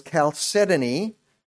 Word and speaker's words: chalcedony: Chalcedony, which it took me a while to learn how to pronounce that, but it's chalcedony, is a pale blue, even a greenish color.